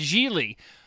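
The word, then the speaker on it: Gigli: And even in the movie, they're like, what's your name, Gingley? He's like, no, it's Geely.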